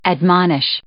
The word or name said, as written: admonish